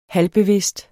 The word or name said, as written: halvbevidst